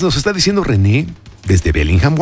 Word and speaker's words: Bellingham: René Sarmiento Lozada, ¿no sabes cómo me acordé de ti con este Bellingham o Bellingham, como se pronuncia a la inglesa?